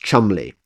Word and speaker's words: Cholmondeley: And it's particularly the case among upper class names like Cholmondeley, which is pronounced Chumley,